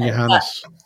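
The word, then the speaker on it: Johannès: I think I was really, really impressed with how France played without, is it Marine Johansson or something? I'm not sure how to pronounce it. Marine Johannes.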